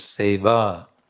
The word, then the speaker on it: seva: And then the third part of the formula, you all know, sevaya. Again, this is a grammatical adjustment for the context. Otherwise, the word is seva. Seva. With a long A.